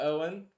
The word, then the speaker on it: Eoin: Welcome to Owen. We're excited. Owen's related to Chris and I. He's our cousin's kid. Not that it super matters, but E-O-I-N is pronounced Owen.